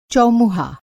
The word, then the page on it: चौमुहा: Hindi